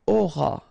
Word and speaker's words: auras: J'aurai, remember, A and O together will produce this O sound, A-N-I-E, aurait, alright, then tu auras, final S not pronounced, aura, il aura, elle aura, okay, nous aurons, final S not pronounced, O-N-N together,